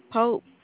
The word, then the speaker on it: Pope: D-E-S-T-I-N-Y. – And what's your last name? – Pope, P-O-P-E.